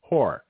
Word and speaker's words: Hoar: Or there's a politician whose last name is H-O-A-R, pronounced whore.